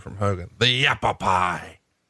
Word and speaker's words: Yavapai: But, you know, who else other than a nerd like me who loved to read about history would know what a Yavapai Indian strap match would be? – No, who came up with that?